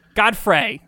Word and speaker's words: Godfrey: So yeah, I'm not a big fan of Linda Gottfried. – No. Oh. – Godfrey, sorry.